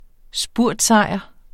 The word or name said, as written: spurtsejr